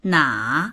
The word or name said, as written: na3